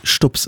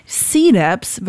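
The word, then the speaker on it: CDUBZ: C-Labs wird er ausgesprochen. Das C wird einfach ausgesprochen wie das englische C halt.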